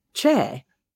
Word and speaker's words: Choi: Well, as for these Korean names, we've already talked about the name Che, which is commonly spelled C-H-O-I and pronounced Choi in the United States. – Right. – But in Korean, it's really more like Che.